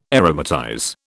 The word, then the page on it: aromatize: British English